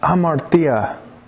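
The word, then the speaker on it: amartia (ἁμαρτία): Where it says in verse 27 that you've been weighed in the balances and found wanting, there's a word called sin, and the word in the original language is pronounced amartia. That's how you'd say it.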